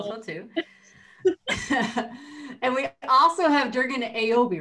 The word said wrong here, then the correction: Jürgen Abo: The one that I go with is Jürgen Abo. – Jürgen Abo.